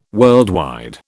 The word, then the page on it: worldwide: British English